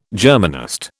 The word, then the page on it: Germanist: British English